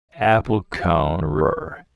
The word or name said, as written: apple corer